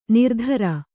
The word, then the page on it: ನಿರುತ್ತರೆ: kannada